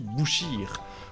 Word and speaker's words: boucher: Sauf qu'en Picard, le mot « cher » se prononce « kir » et le mot « boucher » se dit « bouchir ».